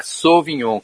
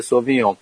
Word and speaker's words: Sauvignon: No francês, a U pronuncia como O. Então é Sauvignon, Cabernet Sauvignon.